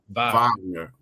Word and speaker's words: Wagner: Like this guy, Franz Wagner, Wagner, whatever, however you want to pronounce it. – Wagner.